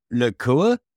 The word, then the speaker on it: Nacua: How do you say his last name? – Nacua. – Nacua. Is that right? Nacua. Yeah.